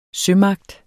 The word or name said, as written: sømagt